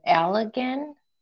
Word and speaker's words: Allegan: So Sara Knight lived in Allegan, I think that's how you pronounce it, County. – Yep.